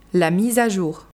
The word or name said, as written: la mise a jour